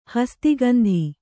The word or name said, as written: ಹಸ್ತಿಗಂಧಿ